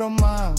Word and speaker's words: рома: Ни цыгане, а рома, ударение на а.